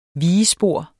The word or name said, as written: VIGESPOR